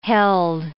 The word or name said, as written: held